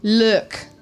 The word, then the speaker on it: lewk: Look. – Look? – Look. – Could you spell that, please? – L-E-W-K.